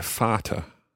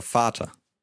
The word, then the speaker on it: Vater: And that's a reminder that V, when it's written down, is pronounced as a F sound, like an F. And we've heard this in Der Vater. – Der Vater.